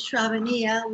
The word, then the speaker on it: Sravaniya: My name is Sravaniya, which rhymes with Mamma Mia, so it's not that hard to pronounce. And Sravaniya DiPecoraro, and I'm an initiated disciple of His Divine Grace A.C.